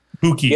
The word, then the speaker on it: buchi: I believe it's called cinque buche. Buchi. Yep.